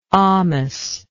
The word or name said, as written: almous